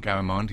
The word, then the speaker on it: Garamond: Garamond? – Yeah, absolutely. Garamond, yeah.